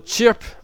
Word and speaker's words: CHRP: et ça se prononce chirp en anglais, ça veut dire pépiment, donc c'était avant Twitter.